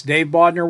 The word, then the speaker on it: Dave Bodnar: Yes, Dave Bodnar works fine.